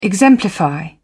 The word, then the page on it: exemplify: British English